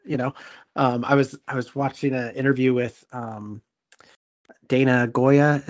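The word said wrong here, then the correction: Gioia: Is that how you say his name? – Joya. – The poet? Joya, yeah.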